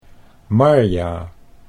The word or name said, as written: Marja